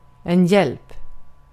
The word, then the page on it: hjälp: [ˈjɛlp]